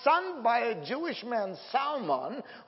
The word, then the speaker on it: Salmon: S-A-L-M-O-N in English is a fish, which is salmon. The L is silent. But in the genealogical name, and I even checked this online for the correct Hebrew pronunciation, there was a man named Salmon. S-A-L, you pronounce the L there phonetically.